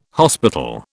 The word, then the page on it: hospital: British English